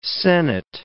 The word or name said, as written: senate